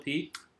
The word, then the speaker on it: Peet: You said Bill Peet? – Yeah, but it's spelled P-E-E-T instead of P-E-T-E. – Like the coal.